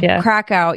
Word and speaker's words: Kraków: Krakow. – That was incredible. Krakow.